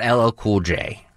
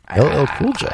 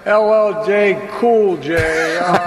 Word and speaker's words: LL Cool J: So he was talking about LL Cool J. – LL Cool J.